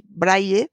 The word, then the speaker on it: braille: Y la pronunciación adecuada en español de este sustantivo es braille. Braille, escrito y pronunciado con Y, braille. Y no otras cosas que decimos como braille, Braille, Braille, se oye muy a menudo.